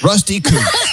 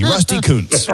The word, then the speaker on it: Rusty Kuntz: Rusty Kuntz. It's spelled K-U-N-T-Z. Rusty Kuntz.